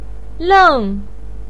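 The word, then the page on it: leng4: pinyin; tone 4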